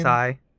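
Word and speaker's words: Sae: is it Sei? Sei? – Game pronounces it as Sai.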